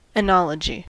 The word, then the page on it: oenology: American English